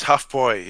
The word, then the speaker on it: Hofbräu: You say Hofbräu? – It's Hofbräu, yeah. Hofbräu. Yeah, when you see that umlaut, the dots of the A followed by a U, it's like an O-Y in English.